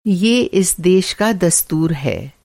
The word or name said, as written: दस्तूर